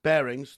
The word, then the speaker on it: Barings: Barings, thank you.